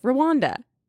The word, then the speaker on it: Rwanda: Rwanda. – Okay, so you actually just say it how it's spelled.